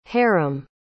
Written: harim

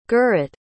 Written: gurit

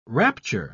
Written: rapture

